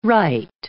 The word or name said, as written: wright